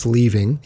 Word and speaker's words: Lee Ving: There's Lee Ving. I am pronouncing his name correctly. It's Leaving. He is the blackmailer Mr. Body.